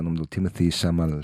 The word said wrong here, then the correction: Chalamet: Chamalé, Chalamet. Chalamet, obrigado.